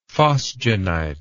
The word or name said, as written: phosgenite